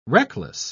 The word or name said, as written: reckless